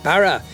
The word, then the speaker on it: Barrow: See Barrow and be a feel for... – Barra. – Yeah, the pronunciation's all together different, I gather, down there.